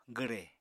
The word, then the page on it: gwre: apatani